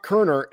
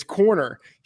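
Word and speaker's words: Koerner: But I will say, Tom, you didn't pronounce Sean's last name correctly. It's not Kerner, it's Corner.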